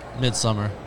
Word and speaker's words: Midsommar: How do you pronounce it? Midsommar? – Midsummer. – Midsummer? – Yeah. – Well, it's a different language.